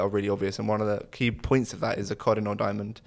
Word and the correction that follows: Kohinoor: which is, did I say that right, Amy? – I would say Kohinoor. – Kohinoor. – There we go. Kohinoor diamond. You kind of got it, yeah.